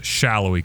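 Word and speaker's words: Salloi: talking about when Tutino says Shallowy. Out of nowhere, after saying it wrong a couple times in the second half, even to that point, he says, Daniel Shallowy, and I'm like, whoa!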